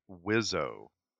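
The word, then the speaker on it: Wiseau: By the way, I just looked it up, and Tommy insists that we pronounce his last name Wizzo.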